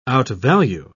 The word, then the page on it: outvalue: British English